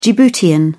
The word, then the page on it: djiboutian: British English